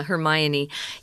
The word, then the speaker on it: Hermione: Yeah, Hermione.